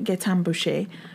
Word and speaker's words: Gaétan Boucher: So when she happened to see Gaétan Boucher, yeah, that's how you say it, Gaétan Boucher, competing in his last race in the 1988 Winter Games, he was the defending champion at the time, but he ultimately lost.